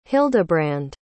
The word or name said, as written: hildebrand